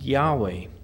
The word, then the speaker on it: Yahweh: Now, the Hebrew tetragrammaton, YHWH, or Yod Heh Wah Heh, is pronounced Yahweh.